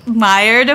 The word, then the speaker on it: Maillard: So with the fermentation time, I think it's part of the Maillard effect.